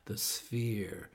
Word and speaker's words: The Sphere: Jag tycker det är svårt att uttala. S-P-H-E-R-E. The Sphere.